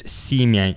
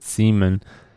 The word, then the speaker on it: simian: In monkeys, it is simian, simian, S I M I A N. Oh, they said semen. No, no, simian.